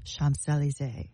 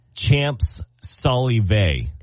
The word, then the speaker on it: Champs-Élysées: Say it like an American. It's Champs-Élysées. – No, and if you said it like an American, it would be Champs-Élysées or Elysee. – That's what I said. – E-L-Y-S-E-E.